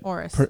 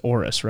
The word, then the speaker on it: Oris: Oris. – It's Oris, right? O-R-I-S.